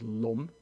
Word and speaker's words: LAM: And if from bottom up, there's the base chakra, which is red and is called LUM, although it's written LAM, L-A-M.